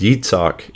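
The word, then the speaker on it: Yitzhak (יצחק): Now that name Isaac, it's actually pronounced Yitzhak in Hebrew.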